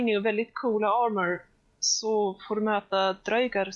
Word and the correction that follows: draugar: Och ja, det uttalas dröjgar och dröjgur.